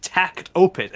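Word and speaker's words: Takt Op.: Tact OP, pronounced Tact Opus, as well as Tact Opus project, which depicts music cart girls who battle with classical music.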